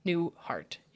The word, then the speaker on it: Newhart: Newhart.